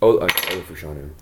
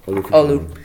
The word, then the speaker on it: Olu Fashanu: Olu Fashanu. – Yeah. – You say Olu. – Olu.